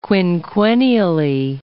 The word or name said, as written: quinquennially